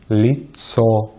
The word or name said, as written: лицо